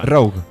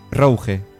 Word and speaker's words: Rogue: Bueno, en inglés creo que es Rogue. – Es Rogue. – En inglés. – Rogue. – Pero sí, hay gente. Yo me acuerdo de la clase de World of Warcraft, Rogue, que es el ladrón, podríamos decir, el pícaro, Rogue, lo que sea. Pues yo me acuerdo que en World of Warcraft había gente, macho, que le llamaba Rouge, Rouge. En francés, Rouge.